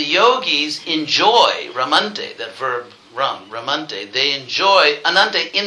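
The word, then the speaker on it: Ram (रम्): And that's directly stated in another verse describing the word Rama, because the Sanskrit root rum, not the Caribbean beverage, but the Sanskrit root, not to be confused. Okay. But it's pronounced the same way.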